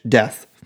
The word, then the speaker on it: Deth: His last name is D E T H. Death.